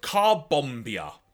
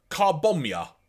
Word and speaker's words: Carbombia: Or, as people seem to keep trying to pronounce this, Andy, Carbomia. Or Carbomia? Like, they kept trying to pronounce it in a different way that I can't actually remember off the top of my head. – Yeah, yeah. I feel like they tried to go with Carbomia, but it's...